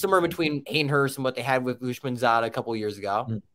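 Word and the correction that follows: Uzomah: Mispronounced his name, just completely. He went to the Jets and did nothing. – Uzoma, CJ, Uzoma.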